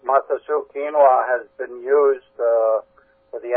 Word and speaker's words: quinoa: Quinoa. – Pardon me? – It's called quinoa.